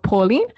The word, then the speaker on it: Pawlean: but right now it's called Pauline, but it's spelled Pawlean, which is sort of like a little spin-off from my name, and I have like matching emojis with it.